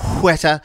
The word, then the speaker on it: Weta: wetter. – Because fully... that's how they say it in New Zealand. You have to say it the way they say it in New Zealand. Otherwise, you're saying it wrong.